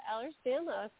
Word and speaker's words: Ehlers-Danlos: Ehlers-Danlos. – Very good. Yeah, Ehlers-Danlos syndrome.